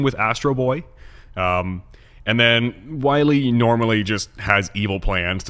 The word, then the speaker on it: Wily: It's spelled W-I-L-Y, which looks like Willy, but apparently it's Wily.